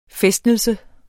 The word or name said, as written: fæstnelse